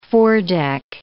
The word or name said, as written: foredeck